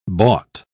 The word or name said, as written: bought